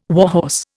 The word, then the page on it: warhorse: Indian